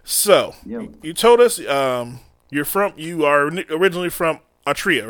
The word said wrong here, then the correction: Eritrea: right? Am I saying that correctly? – Atria. Eritrea.